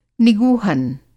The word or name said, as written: निगूहन